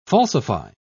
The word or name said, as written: falsify